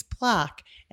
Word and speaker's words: plaque: And plaque, by the way, is closer to the French pronunciation than any of what you Brits say. It's plaque, and that's how the French would like it.